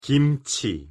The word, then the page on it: 김치: Korean